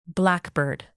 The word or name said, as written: blackbird